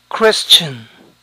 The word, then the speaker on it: Christian: Christian. Christian. Noten que siempre se escribe con mayúscula. E igualmente, algunos suelen pronunciar la S como SH. Christian.